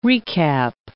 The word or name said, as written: recap